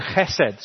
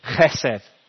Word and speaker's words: chesed: It's the Hebrew word chesed. So if you're a Welsh speaker and you like pronouncing things with a ch on the beginning, you could write that with a C, C-H, chesed.